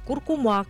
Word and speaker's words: куркума: Ударение в слове «куркума» изменилось. Большой словарь ударения русского языка закрепил вариант «куркума» как основной.